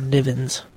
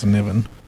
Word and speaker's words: Niven: Okay, so basically, this is a book by Larry, is it Niven or Nivens? – I pronounce Niven.